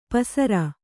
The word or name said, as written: ಪಸಿರ್